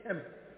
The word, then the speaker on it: Kemet: And all the Egyptologists are saying, we're from Kemet. It's spelled K-E-M-E-T.